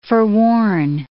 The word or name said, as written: forworn